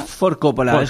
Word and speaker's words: Ford Coppola: Porque lo digo bien, ¿cuatro Coppola sería? – Ford Coppola, como si lo pronunciamos como si fuera el director de cine.